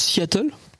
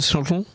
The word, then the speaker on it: Seattle: Tu prononces Seattle Tu prononces pas plutôt Seattle Seattle.